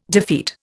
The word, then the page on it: defeat: Indian